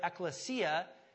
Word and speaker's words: ekklesia: If you want to just phonetically write it out, E-K-K-L-E-S-I-A, ekklesia.